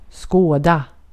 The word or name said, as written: skåda